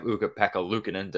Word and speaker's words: Ukko-Pekka Luukkonen: you have Ukko-Pekka Luukkonen. Did I pronounce that right? I'm sure I did. – You absolutely nailed it.